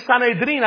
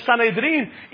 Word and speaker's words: Sanedrín: La ciudad de la muerte de Cristo, la ciudad donde estaba el cuerpo principal de la religión del judaísmo, hermanos, el Sanedrín, así se pronuncia, el Sanedrín.